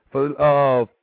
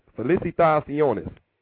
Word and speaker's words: Felicitaciones: Felicitaciones. Felicitaciones. Bam. The proper way to say the word.